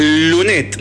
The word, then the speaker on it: Lunettes: Bien, bueno, entonces dijiste que se llama ah. lunet. Lunet. Sí, con doble T. Bien. se escribe lunetes.